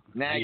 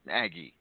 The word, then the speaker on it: Nagy: Nagra? – Nagy. – Niagara? Niaga? – Yeah. Nagy. It's Nagy. His name is Nagy.